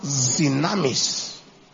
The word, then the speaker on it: dynamis: He said, what is dunamis? I said, it's a Greek word. He said, okay. In Greek, it is pronounced dynamis.